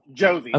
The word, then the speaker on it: Josie: Sorry about Josie, am I saying your name right? Josie? – Yes, Josie.